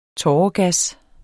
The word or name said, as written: tåregas